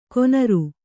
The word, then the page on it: ಕೊನರು: konaru